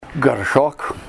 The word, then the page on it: garshok: Ninilchik Russian